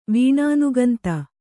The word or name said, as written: ವೀಣಾನುಗಂತ